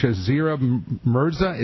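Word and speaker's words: Shazia Mirza: And EcoTalk's Betsy Rosenberg, British-Pakistani Muslim comics Shazira Mirza, is that how you say it? – I think so, yeah.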